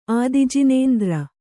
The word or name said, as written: ಆದಿಜಿನೇಂದ್ರ